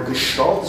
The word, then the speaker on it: Gestalt: So today I want to talk about Gestalt psychology, or Gestalt is probably the way you're supposed to pronounce this. Gestalt's a German word, which probably shouldn't surprise you, because, you know, Germans, as we know, have been exceedingly important and all this stuff.